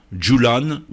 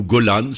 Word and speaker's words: Golan: 20 km de large, plus de 60 km de long, c'est le plateau du Golan, Djoulan ou Golan, selon les prononciations locales,